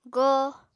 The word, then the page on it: go: Apatani